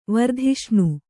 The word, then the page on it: ವರ್ಧಿಷ್ಣು: vardhiṣṇu